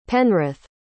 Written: penrith

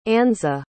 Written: annze

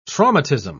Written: traumatism

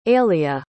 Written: aelia

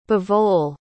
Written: bavol

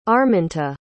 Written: arminta

